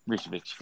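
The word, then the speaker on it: Vučević: Is it Vucevic? – Vucevic, there we go.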